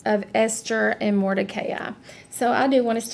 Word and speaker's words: Mordecai: And I know a lot of people say Mordecai, but I really have looked at this word. I really looked at pronunciations, and it really is Mordecai.